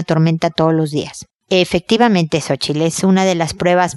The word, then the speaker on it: Xochitl: Xochitl la pronunciación adecuada.